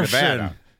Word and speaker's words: Nevada: In Nevada, Iowa, they get really pissed if you pronounce it like look, spelled like Nevada.